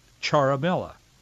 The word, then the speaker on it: Ciaramella: His last name is spelled C-I-A-R-A-M-E-L-L-A, but it's pronounced Charamella.